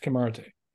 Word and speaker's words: Kimarite: There are not enough Kimarite. – Okay. – Or Kimarites, if you're in Texas. – Kimarite. – Kimarites. No, don't pronounce that middle one. It's silent. That Kimrat.